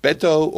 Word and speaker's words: Beto: Finally, Beto O'Rourke.